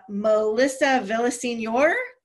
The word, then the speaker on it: Melissa Villaseñor: Melissa Villaseñor.